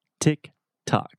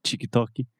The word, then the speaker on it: TikTok: So before we get started talking about the platform itself, just a quick pronunciation about the name of the platform TikTok. So this is not Chikitoki, not anything like that. TikTok.